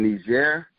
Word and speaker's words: Niger: and your wife is from Niger, that's how you pronounce it.